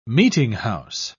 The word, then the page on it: meetinghouse: British English